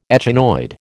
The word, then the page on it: echinoid: American English